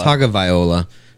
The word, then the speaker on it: Tagovailoa: Sounds like Tua Tagovailoa. Did I say that right? – Tagovailoa.